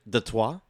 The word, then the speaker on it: Detroit: Detroit, which, by the way, Wembanyama pronounces it the Trois, which I think is very classy as a Frenchman who's never heard someone say the word Detroit before.